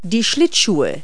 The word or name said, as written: skates